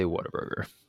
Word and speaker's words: Whataburger: How do you say it? Whataburger or water? – I just say Whataburger. – You just have to say it really fast and.